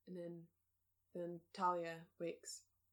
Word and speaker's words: Talia: Or actually, I should say Thalia, because it's how they say it in the movie, and I hate it. And I understand Thalia. That's how a lot of people pronounce the name.